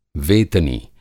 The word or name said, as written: वेतनी